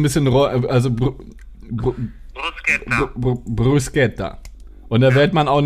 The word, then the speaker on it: Bruschetta: Wie wird es ausgesprochen? Bruschetta, Bruschetta oder Bruschett? Bruschetta. Bruschetta? Ja. Das R also auch. Die Bruschetta sagen, Alter. Das R so.